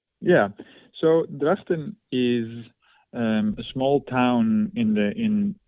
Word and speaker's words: Drachten: There is in Dutch, there is this guttural G, which hurts the throat a little bit, you know, the G. So it's Drachten, but it's good.